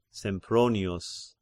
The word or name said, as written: Sempronius